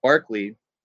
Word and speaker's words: Berkeley: most dramatically with Barclay, and uh, it's spelled Berkeley, the, the place in California is appropriately named after him, uh, who said that there's no such thing as matter.